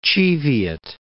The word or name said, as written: Cheviot Hills